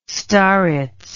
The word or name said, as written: starets